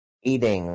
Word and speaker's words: 8ing: Eating, like the number 8 and eating. – Then ING.